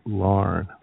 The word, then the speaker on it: Laugharne: which we have mispronounced numerous times until we were told, just make it easy and call it Larne.